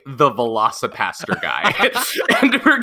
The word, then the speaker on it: Velocipastor: Joining us, though, is a writer-director of the film The Velocipastor. That's right. I said that correctly. It's called The Velocipastor.